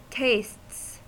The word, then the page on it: tastes: American English